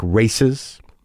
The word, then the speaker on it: RAICES: It was set up by a group called RAICES, that's R-A-I-C-E-S, Texas, Refugee and Immigrant Center for Education and Legal Services. It's pronounced like RAICES.